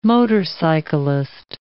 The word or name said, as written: motorcyclist